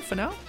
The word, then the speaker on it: Finau: Finau? – Finau? – Yes.